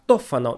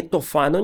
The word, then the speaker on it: Тофано: Итальянцы произносят это имя как Тофано, но вы уж простите, я сохраню то произношение, к которому приучил меня Ерорт Масс, иначе говоря, третий участок, где мы обсуждали когда-то Декамерон с дядей Араиком. Так вот, сказанный Тофано ни с того ни с сего принялся ревновать свою жену, красавицу Монну Гиту.